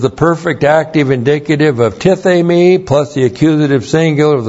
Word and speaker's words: tithemi: The verb here is tithemi. Looks like that in the Greek, T-I-T-H, long E-M-I, tithemi.